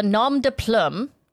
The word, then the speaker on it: nom de plume: Under the nom de plume. Did I say that right? Do you like that? – You got it.